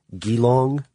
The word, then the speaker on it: Geelong: It was received in the Ford Australia plant in, I'm going to mispronounce this, Geelong, Victoria. – Close enough.